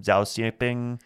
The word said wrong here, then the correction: Xi Jinping: the leader of China, the president of China? – Xi Jinping? – Yeah, that one.